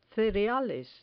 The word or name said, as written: cereales